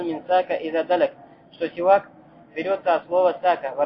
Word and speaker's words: سواك: Ас-сивак произносится с касра ас-син. Так сивак.